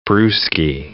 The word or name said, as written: brewski